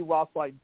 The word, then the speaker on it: Roethlein: Rothlein Poet, R-O-E-T-H-L-E-I-N. Like I said, it's not pronounced the way it's spelled.